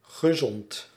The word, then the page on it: healthy: /ˈhɛl.θi/